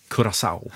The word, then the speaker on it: Curaçao: Jag har fått stöd av producenten att man ska tydligen uttala det Curaçao.